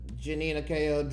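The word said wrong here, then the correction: Jhené Aiko: dropped her, I might have said it wrong. I do apologize. – Janae Aiko, excuse me. Thank you, executive producer, for correcting me.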